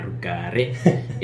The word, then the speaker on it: Rugare: Rugare, you really got to roll the R. – You have to roll the R. Rugare is my name.